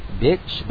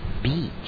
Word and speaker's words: beach: Don't say bitch, but beach. – Okay. – It should have a long E sound, okay?